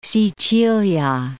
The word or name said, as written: Sicilia